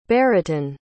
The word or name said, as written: beretun